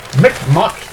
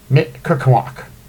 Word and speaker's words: Mi'kmaq: no charges in Proud Boys incident at Mi'kmaq ceremony.